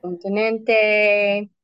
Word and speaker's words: continente: How do you say that? Continente? – Continente, tradition, tradición, migrant, migrante.